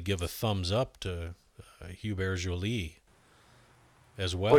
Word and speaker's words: Hubert: And if you're really into French, the H is silent. So it's Hubert.